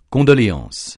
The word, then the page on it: condoléances: francais